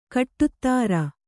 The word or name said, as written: ಖಡತರ